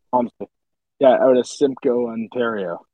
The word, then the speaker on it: Walmsley: Once the Walmsley happened, by the way, for Niagara region or anyone wondering with pronunciation, it's Rick Walmsley. I've heard some Wamsleys. It's Walmsley. Norfolk guy.